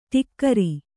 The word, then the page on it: ಠೋಕರ: Kannada